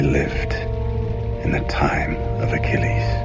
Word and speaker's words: Achilles: A가 발음이 잘 안 들려요. 모음이라서. 그래서 킬레스. – 킬레스. – 킬레스. 이렇게 들립니다. 잘 들어보시죠. 킬레스.